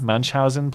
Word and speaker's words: Munchausen: To have their how do you say this word, Munchen or Munchausen? – Munchausen, please.